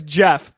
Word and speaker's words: Gef: his name is Jeff, but it's spelled G-E-F.